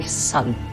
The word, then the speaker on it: son: A tutaj usłyszeliśmy my son, czyli dokładnie taka sama wymowa syna jak słońca.